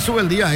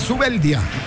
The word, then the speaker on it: Zubeldía: Pero últimamente los españoles me dicen, no es Zubeldía, es Zubeldía, como hay que pronunciarlo, y bueno, nos acostumbramos un poco también nosotros a la entonación que se le da a cada nombre.